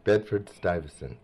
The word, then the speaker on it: Bedford Stuyvesant: Bedford Stuyvesant.